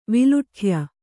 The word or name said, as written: ವಿಲುಠ್ಯ